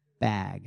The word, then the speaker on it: bag: And I said, say bag. And he said, big. And I said, bag. And he said, big. And I said, okay, come on. Bag, make that like bat, cat, and he'd say bat, cat, okay, bag, big.